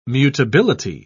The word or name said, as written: mutability